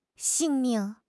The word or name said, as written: 性命